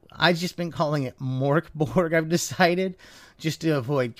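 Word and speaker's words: Mörk Borg: It's the Borg part the Borg? Apparently the G is more of a Y, so it's Morkborg. If I'm pronouncing that correctly, it's more Morkborg.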